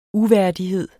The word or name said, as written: uværdighed